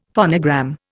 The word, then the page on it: phonogram: Indian